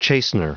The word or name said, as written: chastener